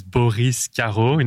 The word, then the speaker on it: Boris Carreau: So my name is Boris Carreau in a French accent. – There you go. And you say it really well. You say it like a proper French person.